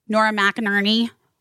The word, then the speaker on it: Nora McInerney: Nora McInerney.